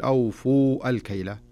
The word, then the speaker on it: أوفوا: لو يلحظ القارئ إلى المصحف يجد أن هناك ألف مستديرة وألف بعدها، وهنا الألف الأولى التي في أوفو ما تنطق، خلاص أدغمت في اللي بعدها. أوفو الكيلة، ما تقول أوفو الكيلة، لا يصح أن تقول أوفو الكيلة، تقول أوفو الكيلة على طول، فهنا تدغم الألف الأولى في الألف الثانية وتصبح ألفا واحدة. لكن إذا وقفت